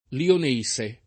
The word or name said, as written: lionese